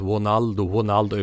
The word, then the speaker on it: Ronaldo: Jag menar, brassarna här, Ronaldo till exempel, ska uttalas Ronaldo utan R-ljud i Brasilien.